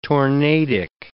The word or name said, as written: tornadic